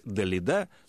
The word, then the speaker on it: Далида: Так появилась «Далида» с ударением на последний слог.